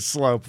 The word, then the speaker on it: Sloop: It's spelled really dumb. I just saw it on his name tag. – SL, it's like a soup with an L. – Sloop? – Yeah, I know. – Frank Sloop. – He pronounces it Slope, though.